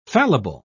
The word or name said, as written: fallible